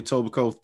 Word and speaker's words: Etobicoke: Did I say it right? Etobicoke? – Etobicoke Thunder, bro. – Bro. Yo, most people, they'd be like, Etobicoke Thunder. Etobicoke. Oh, man. – Facts. – The K-E are silent.